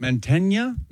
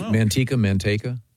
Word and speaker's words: Manteca: Is it pronounced Manteca, California? – I don't know. I'm not familiar with it. – Manteña? – It's Manteca.